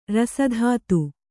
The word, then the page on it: ರಸಧಾತು: rasa dhātu; kannada